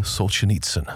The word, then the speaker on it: Solzhenitsyn: Solzhenitsyn.